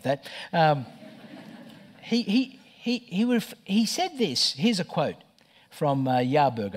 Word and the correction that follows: Harburg: I think is his name. I might have mispronounced that. Harburg.